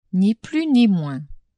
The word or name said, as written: ni plus ni moins